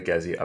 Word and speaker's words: Gezi: Can you talk a little bit about the Gezi uprising? Apologize if I'm pronouncing that incorrectly. – No, you are pronouncing it perfectly well.